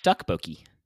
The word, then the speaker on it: Tteokbokki: I found out that it's actually pronounced Tteokbokki. – Okay. – Oh, do you have a different one? Your face is not registering agreement. Oh, no. – No, it is. Okay. It is tuck, I think. Yeah. – Tuck? – Yeah. Like, starts with a T sound, right?